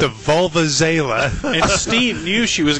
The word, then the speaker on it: Vuvuzela: The term she's trying to come up with is Vuvuzela. She had a different pronunciation.